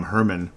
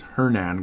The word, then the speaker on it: Hernan: I keep calling him Herman, but it's Hernan Gomez.